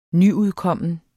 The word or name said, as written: nyudkommen